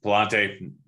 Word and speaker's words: Pallante: We got Alcantara going up against, I can never remember how to pronounce his name, Pinella. Um, Pallante, that's it.